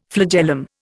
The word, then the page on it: flagellum: Indian